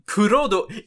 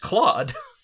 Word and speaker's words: Claude: Like, Kurodo, it's just Claude. It's Claude. It's the French pronunciation of the name, which is Claude.